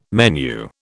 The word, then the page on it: menu: British English